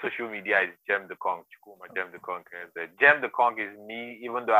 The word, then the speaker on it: Gemdeconq: So I think you said the name of the barrow business, your barrow business is Gemdeconq. Am I pronouncing it right? – Yeah, Gemdeconq.